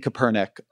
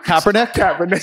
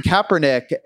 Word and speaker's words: Kaepernick: Did I say it wrong? And it's funny because I've already had it corrected, and I'm not a sports fan. Kaepernick. Sorry.